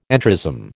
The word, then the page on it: entrism: American English